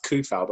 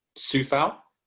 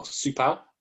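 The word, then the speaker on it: Souček: It looks like it's Kufal, but apparently it's pronounced Sufal or Supal, like that.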